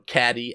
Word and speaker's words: Caddy: Alright, this one's from Caddy Avram. It's spelled Katie, but it's pronounced Caddy because of the thing.